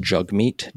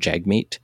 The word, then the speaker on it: Jagmeet: His profile, when you go to Twitter, notes that it's pronounced Jugmeet, despite the fact everyone in the media, or many people in the media, keep saying Jagmeet, and I'm trying my best.